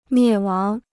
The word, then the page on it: 灭亡: miè wáng